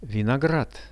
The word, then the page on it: vinograd: Russian